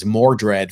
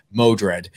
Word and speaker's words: Modred: This is the first appearance of Modred the Mystic, which I mispronounced as Mordred for, I think, you know, decades before I finally actually saw that it was Modred.